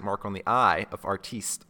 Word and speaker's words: artiste: the word artist was properly pronounced as it is in his home country of wherever he's from. Um, and so he wants to be, have it pronounced as what? um, artiste would be uh, would be suffice.